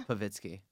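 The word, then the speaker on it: Povitsky: Zinsky. I said that right, didn't I? – Pavitsky.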